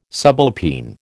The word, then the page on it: subalpine: American English